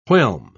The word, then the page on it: whelm: British English